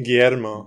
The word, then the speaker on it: Guillermo: Guillermo.